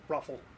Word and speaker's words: ROFL: R-O-F-L. – That's pronounced Roffle? – Roffle. Yeah. Roffle. Very cool.